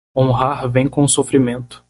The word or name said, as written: sofrimento